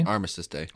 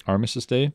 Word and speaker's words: Armistice Day: Armistice Day? – Armistice Day. – Armistice Day.